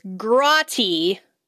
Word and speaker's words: grotty: This month, our glossary term is grotty. Rhymes with potty, meaning dirty, gross, or grody, as in Keanu Reeves must be pretty grotty since he only bathes once, maybe twice a week.